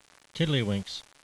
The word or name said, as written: tiddlywinks